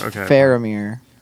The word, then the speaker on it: Faramir: I'm pretty sure it's pronounced Faramir.